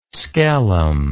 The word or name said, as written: skellum